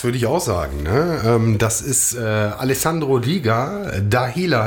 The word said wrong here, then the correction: Dahlia: heißt der Track, wenn ich es richtig ausspreche. – Nein, Dahlia. – Oh, ja. – Dahlia, und das ist die Blume.